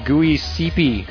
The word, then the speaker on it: Giuseppe: Didn't I say Giuseppe? Or did I say Giuseppe? – No, you said it right.